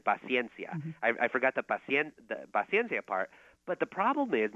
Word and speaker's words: paciencia: And I said it was paciencia. And actually, it's paciencia. That's how you officially pronounce it in Spanish.